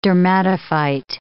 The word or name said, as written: dermatophyte